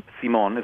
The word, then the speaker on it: Simon: Simon, or Simon is the way you pronounce it,